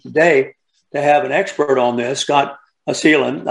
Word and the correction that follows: Cleland: It's Cleland. – Cleland. Cleland. Like Cleveland.